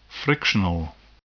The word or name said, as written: frictional